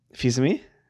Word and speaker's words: Fils-Aimé: Fils-Aimé? – Yeah, you had it right.